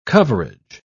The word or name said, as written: coverage